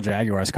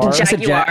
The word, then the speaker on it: Jaguars: Pronounce the hard U in Jaguars? – I did. – Jaguars. – I said Jaguars.